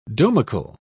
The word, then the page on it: domical: British English